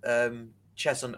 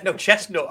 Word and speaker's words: Cheshunt: Oh, you have done your research, because I've been waiting for you to pronounce it Cheshunt, and I was going to jump all over you, mate. – Chestnut. I thought Chestnut was the first one.